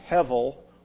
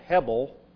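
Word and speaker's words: hevel (הבל): That word empty may be used in the sense of a pun or a play on words, because the Hebrew word is hevel, or can be pronounced, or it's spelled hebel, and it may be a play on the word Baal, the god that they followed.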